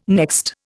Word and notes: next: Indian

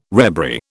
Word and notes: rebury: British English

